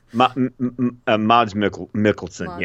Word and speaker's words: Mads Mikkelsen: Hopefully I'm saying that right. I can't say his name or the character name. – Mads Mikkelsen, yes. Love him.